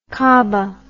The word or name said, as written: caaba